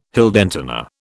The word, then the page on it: Heldentenor: British English